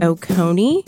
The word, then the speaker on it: Oconee: You hosted it in Lake Oconee. Am I saying that right? – Absolutely.